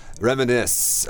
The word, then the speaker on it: reminisce: Right, so reminisce. – Re 后面是 mini, 像 mini cooper 一样 That's smart, that's smart. Okay. – S-C-E. – S-C-E, yeah.